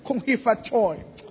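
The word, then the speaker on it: Kong Hi Fa Choi (恭喜發財): So in Chinese, is it all right to say Kong Hi Fa Choi? Happy New Year. I don't know if I pronounce that. Is that all right? Oh, correct.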